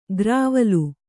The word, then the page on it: ಗ್ರಾವಲು: grāvalu